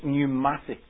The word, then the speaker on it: pneumatic: And of course, the tyres that we have on cars are called pneumatic tyres. Begins with a P, even though we pronounce it without.